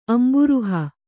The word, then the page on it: ಅಂಬುರುಹ: Kannada